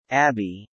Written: Abbey

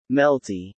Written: Melty

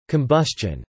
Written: combustion

